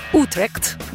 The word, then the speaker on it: Utrecht: E ci sono tre città, bravo Tommy, bello, ci sono tre città che sono bike friendly e sono Utrecht, l'ho detto bene, sì, Copenaghen e Amsterdam, per cui ecco, lì probabilmente sono tutti più tranquilli e contenti.